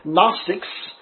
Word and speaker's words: Gnostics: Only when you pronounce it in the form Gnostics, the G is silent.